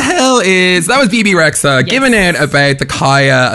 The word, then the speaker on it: Rexha: Is it BB Rexha or BB Risa? I never really knew how to say her name. – Rexha.